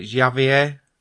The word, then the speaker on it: Javier: So the second character you play is, I don't know how you pronounce this, it's spelled J-A-V-I-E-R, is it Javier? – Javier.